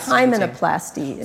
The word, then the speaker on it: hymenoplasty: Explain this hymenoplasty. – So that was in Lebanon. – Lebanon, okay. – Because so. At the same time as women are... – Am I pronouncing that correctly? – Hymenoplasty. – Okay.